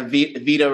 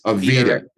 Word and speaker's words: Vita: Yeah, Vita Records. – Vita. – I mean, it's Latin for life. For life records. – Oh, well, I dropped out of Latin class, so let me. – So technically the Latin pronunciation would be Vitae. – Vitae. – But, yeah, but we call it Vita.